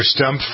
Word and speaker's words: Stumpf: It's Mr. Stumpf. – Stumpf, which rhymes with Trump.